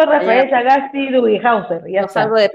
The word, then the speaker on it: Sagasti: Sagaski. Hofhauser. Yeah, Hofhausler. – Ay. – Hausler. – Tengo, tengo que leerlo, tengo que leerlo. – ¿Cómo es? Sagasti.